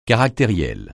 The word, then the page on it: caractériel: francais